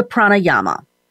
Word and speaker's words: pranayama: It's pranayama, P-R-A-N-A-Y-A-M-A, pranayama.